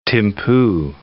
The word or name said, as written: Thimphu